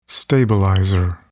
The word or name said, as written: stabilizer